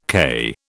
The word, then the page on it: k: British English